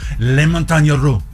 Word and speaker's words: Les Montagnes Russes: Yesterday, I was at King's Dominion riding some Les Montagnes Russes. And I got properly jostled. In fact, I'm pretty sure my spleen has been relocated. – Val says yes. You're saying it right.